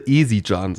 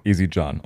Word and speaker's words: Ezidxan: Geschrieben übrigens E-Z-I-D-X-A-N. – Genau, falls die Aussprache falsch ist, sagt es uns bitte. – Ja. – Wir sind uns da auch etwas unsicher. – Wiederhol nochmal. – Also ich würde Ezidjan sagen. – Ezidjan, okay.